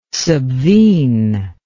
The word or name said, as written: subvene